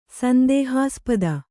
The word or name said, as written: ಸಂದೇಹಾಸ್ಪದ